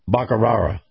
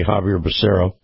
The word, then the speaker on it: Javier Becerra: And then there was Joe Biden's favorite mispronounced name, Javier Becerra, the one that he calls Bacarara. Now, the Health and Human Services Secretary yesterday, Javier Becerra, came out and said, people will die if the Supreme Court knocks down the vaccine mandate.